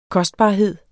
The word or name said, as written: kostbarhed